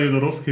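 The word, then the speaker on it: Jodorowsky: Aber das ja, mittlerweile, übrigens war Jodorowski richtig, ich habe es gegoogelt.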